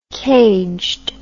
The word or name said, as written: caged